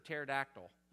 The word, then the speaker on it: pterodactyl: I saw a book one time that said P, it was for teaching the alphabet, and it said P is for pterodactyl. And I was thinking, well, that is not helpful at all, because it's a silent P.